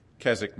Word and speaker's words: Keswick: There is an opposite pole, which is, I'm going to call the Keswick model, that W is silent, Keswick model, where great emphasis is placed on the legal or forensic aspects of salvation.